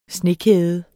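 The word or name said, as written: SNEKÆDER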